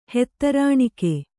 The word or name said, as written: ಹೆತ್ತರಾಣಿಕೆ